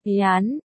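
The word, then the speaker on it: Yán (盐): Diêm đọc là, Yán, trùng cách đọc của Viêm nên bạn cậu lấy đó mà đặt biệt danh cho Tiêu Tê.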